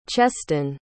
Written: cheston

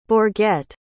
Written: bourget

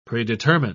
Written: predetermine

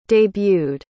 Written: debuted

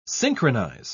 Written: synchronize

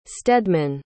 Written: steadman

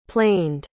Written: planed

